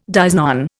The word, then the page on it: dies non: Indian